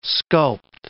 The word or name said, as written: sculpt